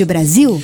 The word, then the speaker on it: Brasil: Ouviu aí, porque Brasil, o nosso Brasil bem brasileiro, como diz a música Aquarela do Brasil que você está ouvindo, se escreve com S, apesar do som de Z, Zil de Brasil.